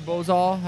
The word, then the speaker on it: Bozal: Was it the Bozal? Am I pronouncing that correct? – Yeah.